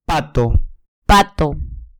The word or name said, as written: pato